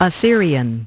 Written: Assyrian

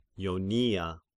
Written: Ionia

